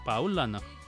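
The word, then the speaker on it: Paulaner: Yo creo que si es alemana se pronuncia así. Paulana. A ver, EU eh, e eh, es, doi. doy. Hoy. AU es AU. El diptongo es AU. Pero la N-E-R nada más se pronuncia no. Paulana. Ah. Paulana.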